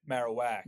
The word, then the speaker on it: Marowak: All right, so my number 7 is, I hope I say this right, Marowak? – Marowak.